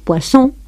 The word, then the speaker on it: poisson: Esto hay que tener cuidado con ello, porque podemos estar diciendo pescado, poisson, o veneno, poison. De modo que ustedes ven como hay que tener mucho cuidado en pronunciar bien esa S sorda o sonora.